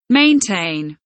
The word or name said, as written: maintain